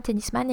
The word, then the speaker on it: tennisman: Un tennisman et une tenniswoman, so admire the French pronunciation there.